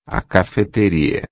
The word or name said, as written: cafeteria